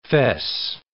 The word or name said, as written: fess